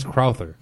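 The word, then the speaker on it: Crouther: Lance Carruthers. Am I saying that right? – I think it's Lance Crowther.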